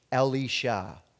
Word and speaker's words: Elisha: So Elisha the prophet's name is in Hebrew is pronounced Elisha.